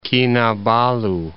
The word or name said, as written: Kinabulu